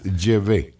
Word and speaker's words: Yahvé: Y esa es la pronunciación un poco cerrada en la A y en la E. – Ni siquiera Yahvé, sino Yahvé. – Sí, es un poco más cerrado que Yahvé. Es Yahvé.